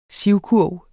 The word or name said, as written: sivkurv